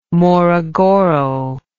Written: morogoro

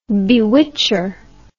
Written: bewitcher

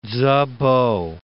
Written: Tzu-po

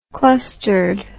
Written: clustered

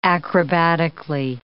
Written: acrobatically

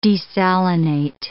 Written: desalinate

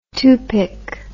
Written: tupik

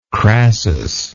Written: crassus

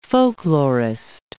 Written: folklorist

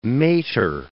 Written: mater

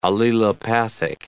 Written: allelopathic

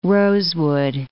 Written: rosewood

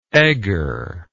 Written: egger

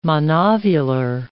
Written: monovular

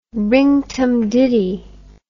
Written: rinktumditty

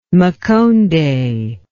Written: Makonde

